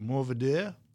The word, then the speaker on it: Mourvèdre: Mourvèdre.